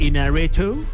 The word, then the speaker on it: Iñárritu: Iñárritu, yeah.